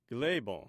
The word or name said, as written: gthebon